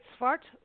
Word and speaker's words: Svart: Svart Records, am I saying that right? – Yes.